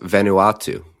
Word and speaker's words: Vanuatu: I believe that's pronounced Vanuatu, actually.